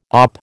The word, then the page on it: OP: American English